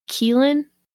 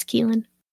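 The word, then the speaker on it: Kelan: They took in Carolyn's teenage nephew, Keelan, I think is how you say it. K-E-L-A-N, I think it's Keelan.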